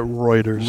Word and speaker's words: Reuters: How do you say that? – I think you say it Reuters. – Reuters. I always say it wrong.